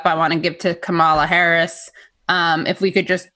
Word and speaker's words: Kamala: I think it's Kamala. – All right, Kamala.